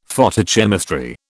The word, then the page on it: Photochemistry: British English